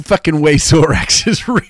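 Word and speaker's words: Xorax: It's spelled X-O-R-A-X. I think it's pronounced Zorax. I think it's pronounced Zorax, which that's how it's spelled. Not Lorax, because that would not be fucking true. Zorax, yes, Zorax.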